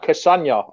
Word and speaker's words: Castagne: It's like Lasagna, Cassagna, I would have said. – I would agree with you. That's how I say it.